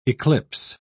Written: eclipse

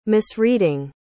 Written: misreading

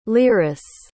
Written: lyris

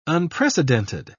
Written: unprecedented

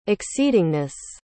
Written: exceedingness

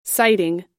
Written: siting

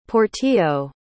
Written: Portillo

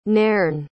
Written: nairne

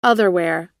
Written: otherwhere

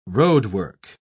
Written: roadwork

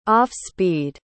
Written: off-speed

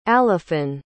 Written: alafin

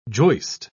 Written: joist

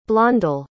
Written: blondell

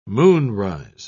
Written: moonrise

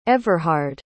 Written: everhard

